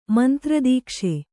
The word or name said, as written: ಮಂತ್ರದೀಕ್ಷೆ